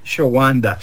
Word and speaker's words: Shawanda: Shawanda. That's right, Shawanda.